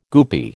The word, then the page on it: goopy: American English